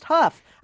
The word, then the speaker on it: Hough: I just listened to the podcast critiquing Safe Haven, and you kept referring to Julianne Hough as Julianne Hough. – Yes. – You're a movie critic, for gosh sakes. This isn't her first movie. Her name is pronounced Huff.